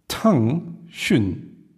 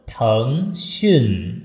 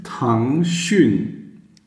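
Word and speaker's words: Tang Xun (腾讯): Also Tang Xun. Einmal hoch, einmal unten. – Tang Xun.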